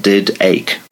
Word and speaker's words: Didache: It's, it's spelled did ache, but pronounced Didache.